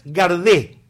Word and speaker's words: Gardet: Contanos un poco, en el Día de la Madre, lo que fue la madre, Berta, castellanizado Berta, Berthe Gardet, como se llamaba en realidad. – En realidad es Garde. Garde. Sí. Pero muy difícil de pronunciar, ¿no?